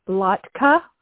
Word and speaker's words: latke: of latke, Did I say that right? – Yes.